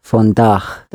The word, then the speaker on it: vandaag: Vandaag. – There's a long A, two A's together. Vandaag.